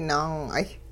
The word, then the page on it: naang'ay: Hupa